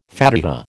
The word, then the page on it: Fatiha: American English